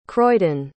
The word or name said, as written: croydon